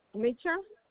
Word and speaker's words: Mitchell: My name is Rosa Mitchell. I tell him yesterday I'm gonna call him today. – Okay, may I have your first and last name? Can you spell that for me? – Rosa Mitchell. – R-O-S-A-M-I-T-C-H-E-L-L.